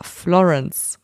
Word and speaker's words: Florence: Wird der Name auch so englisch ausgesprochen. – Ja, Florence.